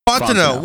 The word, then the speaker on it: Fontenot: It's Fontenot, isn't it? – Fontenot.